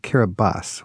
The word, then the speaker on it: Kiribati: Hey, by the way, it's spelled Kiribati. We pronounce it Kiribati.